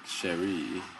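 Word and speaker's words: chérie: You're just pronouncing it wrong. – Oh, how do you say it? – Like, Cherie.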